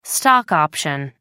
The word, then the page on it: stock option: American English